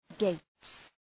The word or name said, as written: gapes